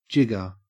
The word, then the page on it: jigger: Australian English